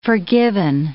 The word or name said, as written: forgiven